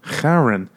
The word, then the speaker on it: Charon: Charon.